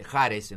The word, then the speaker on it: Ares: No, porque está el caso emblemático de estos cinco chicos de Ares, Jares en realidad, Ares. Se escribe, entiendo que se pronuncia Jares, que fueron condenados a 15 años.